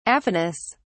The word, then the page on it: aphonous: British English